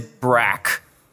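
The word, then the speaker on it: BRAC: What is Brack, as people pronounce the acronym? – Yeah, it's two different things.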